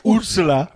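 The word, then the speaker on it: Úrsula: In no particular order, Ursula con un acento en the U. – Ursula.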